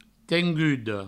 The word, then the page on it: tenguda: tengudo